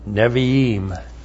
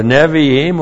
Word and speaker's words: Nevi'im: Joel is located in the Nevi'im. That's the N, Shiva, B, long I, Aleph, long I, M, Nevi'im.